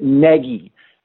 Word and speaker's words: Nagy: On the day he was introduced, I asked him personally off to the side how he said it, and he said it Nagy, as in leggy, N-E-G-G-Y was how I heard it.